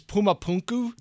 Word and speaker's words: Pumapunku: But for me, the one that sort of turned the corner for me is Pumapunku.